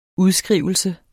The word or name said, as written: udskrivelse